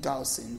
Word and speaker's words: Dawson: My name is Nancy, middle initial J as in Jean, J-E-A-N, Dawson, D-A-W-S-O-N.